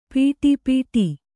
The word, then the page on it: ಪಡುಪಾಟು: paḍu pāṭu